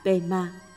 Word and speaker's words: Pema: Padma. Padma, thuật ngữ Sanskrit có nghĩa là hoa sen, phát âm theo tiếng Tây Tạng là Pema.